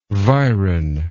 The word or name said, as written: viron